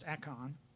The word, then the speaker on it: Achan: For Achan, I'll get it right, that's how it's pronounced, Achan, the son of Carmi,